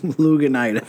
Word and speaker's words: Lagunitas: Lagunitas. – Is that how you say it? – Yep. – Is Lagunitas.